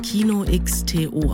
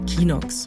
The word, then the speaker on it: Kino.to: Arvid setzt Kino.to auf. Das spricht man übrigens so aus und nicht Kinox.